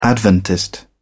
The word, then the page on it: Adventist: British English